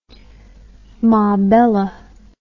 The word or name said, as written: mabela